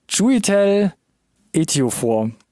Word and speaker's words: Chiwetel Ejiofor: Chiwetel Ejiofor. – Das klang jetzt nicht so richtig souverän, aber 90%, es war, glaube ich, technisch gesehen erstmal richtig. – Probierst du es mal, komm. – Chiwetel Ejiofor.